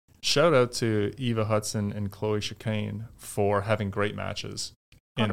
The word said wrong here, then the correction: Chicoine: Am I pronouncing that right? – Chicoine.